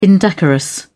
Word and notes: indecorous: British English

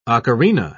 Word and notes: ocarina: British English

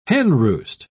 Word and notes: henroost: British English

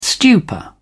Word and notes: stupor: British English

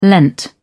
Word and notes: lent: British English

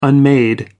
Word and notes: unmade: American English

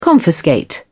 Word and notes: confiscate: British English

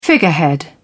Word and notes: figurehead: British English

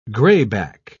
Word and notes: grayback: British English

